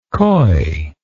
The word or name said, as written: coyness